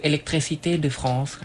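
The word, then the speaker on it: électricité de France: electricité de France ครับ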